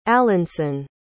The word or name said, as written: alanson